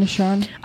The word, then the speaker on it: Nahshon: From the line of Judah, the person that was chosen out was Nahshon. I'm not saying that right. Oh, yeah, Nahshon.